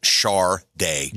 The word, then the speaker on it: Sade: On top of the record, it said pronounced Sade.